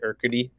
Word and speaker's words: Urquidy: I know we have tonight, we have Dylan Cease versus I can never remember how to say it, Urquidy. – Yeah. – I think you got it right.